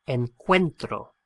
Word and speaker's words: encuentro: It's an irregular verb, and the stress of the pronunciation on that first O splits the O into a U and an E, and we end up with encuentro. E-N-C-U-E-N-T-R-O.